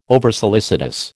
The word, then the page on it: oversolicitous: American English